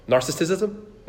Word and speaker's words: narcissism: Maybe you heard of the word narcissism. Narcissism? Narcissism. I added an extra syllable there.